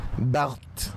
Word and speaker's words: Barthes: Barthes.